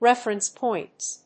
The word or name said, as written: reference points